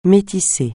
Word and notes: métisser: francais

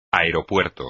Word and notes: aeropuerto: espagnol

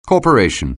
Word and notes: corporation: anglais

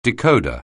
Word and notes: decoder: anglais

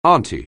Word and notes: auntie: anglais

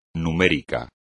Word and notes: numérico: espagnol

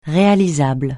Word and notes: réalisable: francais

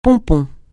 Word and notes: pompon: francais

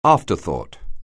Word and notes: afterthought: anglais

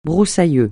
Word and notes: broussailleux: francais

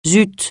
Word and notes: zut: francais

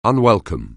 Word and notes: unwelcome: anglais

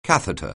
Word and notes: catheter: anglais

